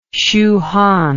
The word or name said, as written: Shu Han